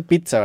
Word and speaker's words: pizza: ¿Tú, cómo, dices que se dice pizza, verdad? – Yo creo que hay una T que no vemos.